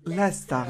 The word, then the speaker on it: Leicester: Lesta. L'avevo pure detta, porca vacca, in una puntata. Lesta City. – Lesta. – Ha detto giusto.